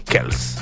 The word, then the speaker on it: Kelce: Porque se escribe K-E-L-C-E, y le dicen Kelce. Pero él dice que originalmente es Kels. Eso bueno, y así es lo correcto.